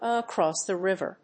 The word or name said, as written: across the River